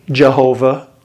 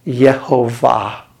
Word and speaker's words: Jehovah: No, it's because in German, they don't say Jehovah. They say Yehovah.